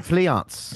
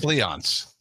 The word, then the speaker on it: Fleance: Banquo and Fleance. – Fleance.